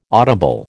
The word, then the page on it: audible: American English